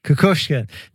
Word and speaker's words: Kukushkin: Kukushkin.